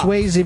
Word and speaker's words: Swayze: And then Swayze because it rhymes with crazy.